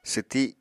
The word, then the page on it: swti: apatani